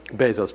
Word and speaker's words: Bezos: Is it Jeff Bezos or Bezos? Bezos.